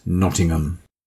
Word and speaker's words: Nottingham: By the way, America, it's pronounced Nottingham.